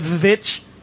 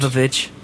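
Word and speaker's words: VVitch: It's made by the same guy who made The Witch. The Witch, but it's spelled with 2 V's. Remember that horror movie? – No. – It's V-V-I-T-C-H. – I don't. – Vavitch. – Mm-mm. – But it's witch.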